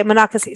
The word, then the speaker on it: Monocacy: is it monocacy am i saying it right i know there's manassas monocacy monocacy monocacy okay monocacy sorry i can't pronounce anything back east yeah.